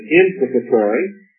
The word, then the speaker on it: imprecatory: The penitential psalms, the imprecatory, we might pronounce it imprecatory psalms,